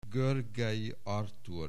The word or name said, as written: Görgey, Artúr